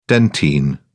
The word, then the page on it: dentine: British English